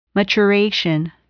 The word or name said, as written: maturation